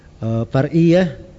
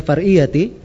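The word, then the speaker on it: فرعية: Far'iyah. Walaupun bisa dibaca far'iyati, tapi di situ disukun ya, karena mengikuti akhir baitnya. Akhir baitnya enggak bisa di kasrah, dia harus disukun.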